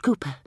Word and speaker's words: Cowper: "It's pronounced Cooper. The W silent."